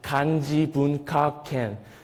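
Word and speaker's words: Kanji Bun Ka Ken (漢字文化圏): These letters are pronounced as a Hanja Mun Hwa Kwon, but this Japanese also pronounced as Kanji Bun Ka Ken. Kind of similar, right?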